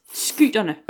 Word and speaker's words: Skyterne: Og på det her tidspunkt, der huserede, og nu kommer vi igen til skal udtale ting, hun ikke helt ved, men skyterne. Ja, helt præcis. Skyterne. Øh, jeg vil godt lige have, at lytterne noteret, at jeg ramte rigtigt i første hug for en gang skyld.